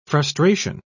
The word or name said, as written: frustration